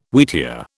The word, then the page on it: wheatear: British English